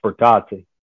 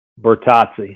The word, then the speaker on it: Bertozzi: Yeah, it's been Bertozzi for a long time in the racing world, but it's supposed to be pronounced Bertozzi. – Oh, my God. Say it one more time. – Bertozzi.